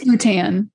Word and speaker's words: Bhutan: So looking at the news today, unfortunately, if we jump over to Bhutan, I need to get better at my pronunciations. – I think it's Bhutan.